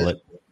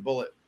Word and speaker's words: Bulleit: I think it is because Bullet. Bar Rescue, they were pushing Bullet whiskey a lot on Bar Rescue, and they always pronounced it Bullet.